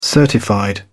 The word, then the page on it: certified: British English